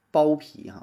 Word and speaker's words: 剥皮: 第一个呢，咱先说说，呃，剥皮啊，剥皮，其实正确的读音呢，应该读成剥皮哈，剥皮就是剥这个橘子皮嘛，叫剥皮啊，但是一说剥皮吧。